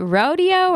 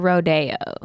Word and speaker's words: Rode: Is it Rodeo or Rodeo? – It's like Rode, R-O-D-E. – Okay. – I don't know how to say it.